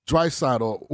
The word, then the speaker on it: Draisaitl: But Leon, and I hope I'm pronouncing his last name, Draisaitl, is that how you correctly pronounce it? – Draisaitl was actually second in the league to Auston Matthews in goals.